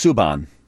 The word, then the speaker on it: Subban: John, it's pronounced Subban.